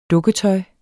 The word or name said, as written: dukketøj